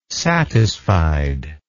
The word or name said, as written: satisfied